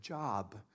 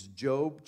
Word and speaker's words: Job: And if you were like me as a kid, I thought it was the Book of Job, because that's how it's spelled. It's J-O-B, but it's a guy's name, Book of Job.